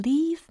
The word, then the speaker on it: leave: Before you leave me, baby. 이겁니다. 당신이 떠나기 전에 내 뺨을 어루만져 주세요. 라는 겁니다. leave me 라고 되게 길게 발음을 해드렸잖아요. L-E-A-V-E, e, 떠나다 라는 의미이기 때문이죠.